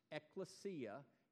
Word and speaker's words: ekklesia: The word that we translate church is a word that you pronounce ekklesia in the original language, in the Koine Greek, ekklesia.